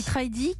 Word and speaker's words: Tridea: Oui, Tridy, qui s'écrit T-R-I-D-E-A.